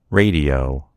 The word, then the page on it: radio: American English